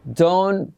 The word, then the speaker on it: don't: Don't put me down. ¿Qué significa? no me pongas abajo. No me humilles. Don't put me down. Pero mirad la pronunciación don, la T se omite, don't, don't put.